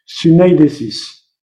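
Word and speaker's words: sunaidesis (συνείδησις): se prononce euh, donc en grec sunaidesis.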